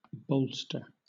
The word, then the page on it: bolster: British English